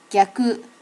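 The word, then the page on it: gyaku: native Japanese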